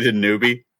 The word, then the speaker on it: Danube: Did you say Danube? – Danube. D-A-N-U-B-E. Danube. – The Danube, I think. – The Danube. – Yeah, the River Danube.